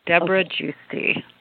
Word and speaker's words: Debra Giusti: Debra Gusti.